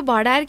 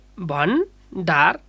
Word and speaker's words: भण्डार: यो भडार कि भण्डार के भन्ने उच्चारण कसरी गर्ने? – यसलाई भण्डार भन्नुपर्छ।